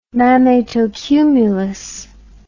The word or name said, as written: mammatocumulus